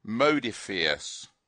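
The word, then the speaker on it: Modiphius: Now, someone told me how to pronounce this. Modifius. Yes. – They've got an umlaut over their U.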